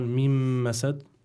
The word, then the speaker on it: من مسد: يعني الشيخ بس لنبين لمستمعين الكرام يعني النون هون من مسد لا تلفظ